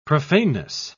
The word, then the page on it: profaneness: British English